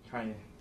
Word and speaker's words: Kanye: Oh, I'm from Ohio. We say Kenny. That's how we pronounce it. – Well, I don't care where you're from. You pronounce my name Kanye.